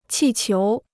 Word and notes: 气球: qì qiú

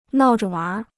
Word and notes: 闹着玩儿: nào zhe wánr

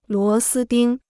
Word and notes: 螺丝钉: luó sī dīng

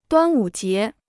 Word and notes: 端午节: duān wǔ jié

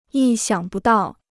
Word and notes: 意想不到: yì xiǎng bù dào

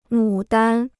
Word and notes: 牡丹: mǔ dan

